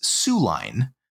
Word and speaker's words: Soo Line: And then finally, the other train game filler that I was able to play was Soo Line by Tom Russell and Holland Spiel Games. This is spelled S-O-O. Like not like S-I-O-U-X, like the Native American tribe. – Or S-U-E. – S-U-E, yep, Sioux.